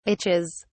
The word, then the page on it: itches: British English